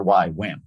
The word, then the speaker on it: YWAM: Oh, the letter Y, wham.